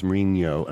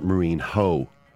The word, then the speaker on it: Mourinho: Jose Mourinho is Mourinho and not Mourinho. You know, like you just have to respect the pronunciation as it is spoken by the people who actually use the word. And not as it appears to you in your, you know, phonetic, you see? – Yeah, I do get.